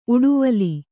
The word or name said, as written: ಉಡುವಲಿ